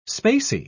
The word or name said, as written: spacey